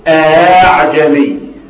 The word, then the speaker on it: أأعجمي: دخل عليها استفهام، أأعجمي، أأنذرتهم، إذا أردنا، أو إذا أردنا، تحقق في كل ذلك إلا في كلمة أعجمي، فإنها تسهل بين الهمزة والألف وجوبا، فنقول أعجمي